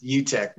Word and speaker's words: Uttech: It's Uttech. You almost nailed it.